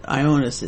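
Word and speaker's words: Ioannis: Ionas Metaxas, Ionas is, that's how you pronounce it, is John in Greek.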